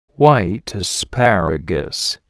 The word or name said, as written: white asparagus